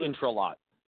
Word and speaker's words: Intralot: And within recent history, the last 2 years, the son of the Greek billionaire who runs a gaming company, at which I'm going to mispronounce, I'm so sorry for a stroke, I do apologize, Inhot's son was killed. – Intralot. – Yes, thank you, right?